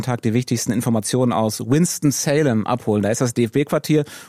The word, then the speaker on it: Winston-Salem: Winston-Salem, genau richtig. Gab schon die unterschiedlichsten Varianten. Es ist nicht Salem, es ist nicht Winston, aber es ist Winston-Salem und hier bin ich jetzt zu Hause.